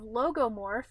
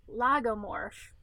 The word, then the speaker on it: lagomorph: So all my life, I thought that they were in the category of logomorph. And as it turns out, I'd been saying that wrong the entire time I've ever existed. And it's actually lagomorph.